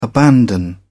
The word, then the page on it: abandon: British English